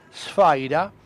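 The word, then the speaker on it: sfaira (σφαῖρα): Es que para los griegos, spaira se, pronuncia sfaira, quería decir propiamente pelota, y solo después tomó el sentido de esfera en general.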